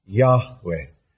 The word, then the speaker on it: Yahweh: Y-H-W-H. – Y-H-W-H. – Which was pronounced something like Yahweh.